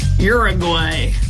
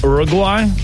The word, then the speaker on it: Uruguay: Well, it doesn't start with a your sound. – Ooh. – Uruguay? – Uruguay?